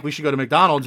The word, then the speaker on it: McDonald's: So they don't pronounce McDonald's. They pronounce it MacDonald's.